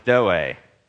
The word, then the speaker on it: zoe: It's always the one, zoe is how I would pronounce it.